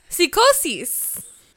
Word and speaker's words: Psicosis: It's Little Guido versus a returning Psicosis. Or... – No. Psicosis. – Yes, Joey Styles has a line of, like, that's the Spanish pronunciation and spelling, more or less going, it's legally distinct.